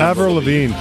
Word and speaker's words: Avril Lavigne: This is Avril Lavigne. – Avril Lavigne.